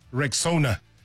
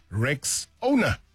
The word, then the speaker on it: Rexona: Now, first things first, it's Rexona. Just like Rexona. Yeah, say it right and say it proud.